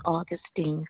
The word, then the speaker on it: Augustine: Yes, my name is Augustine Connolly. – Yes, ma'am. I get your zip code. – 39648. – Yes, ma'am. And you say your name was what again? – Like the month of August, AUGUSTINE.